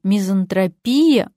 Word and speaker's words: мизантропия: А медики, которые любят ставить в профессиональных словах свои ударения, такие как алкоголь или наркомания, вот-вот присвоят слову «мизантропия» ударение на «и». Давайте говорить правильно. Мизантропия. Ведь иногда так приятно побыть мизантропом.